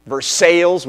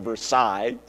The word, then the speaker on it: Versailles: Versailles, which is a horrible mispronunciation of the original Versailles.